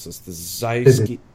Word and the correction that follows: Syzygy: Sorry, go ahead. – Syzygy, thank you.